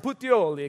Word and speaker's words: Puteoli: And you have to say it like this, like a real Italian. Puteoli, okay? That's how you pronounce it with your hand.